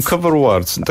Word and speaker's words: Cover Awards: Cover Awards. Tak naprawdę bawimy się trochę językiem, bo nawiązujemy do angielskiego awards, czyli nagród, ale jednak trochę też do cover arts, czyli do okładek.